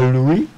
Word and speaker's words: LUI: Im Hörsaal Louis. L-U-I. Louis. – L-U was? – L-U-I. – Okay, wie ihn ausgesprochen gemacht? – Linkte Uni in oder Louis. – Ah, Louis.